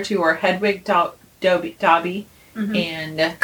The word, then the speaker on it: Dobby: It is Dobby!